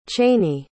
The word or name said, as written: chany